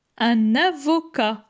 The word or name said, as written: Un avocat